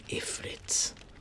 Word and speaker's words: ifrits: These djinn are known as fire ifrits, although I've also heard it pronounced as efrit in places such as Kofa and Al-Haik, so I believe that both pronunciations are correct.